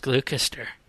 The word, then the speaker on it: Gloucester: It's pronounced Gloucester.